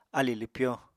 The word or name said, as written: alì lwpyo